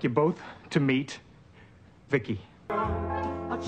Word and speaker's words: Vicki: V-I-C-I, I, I pronounce it Vicky.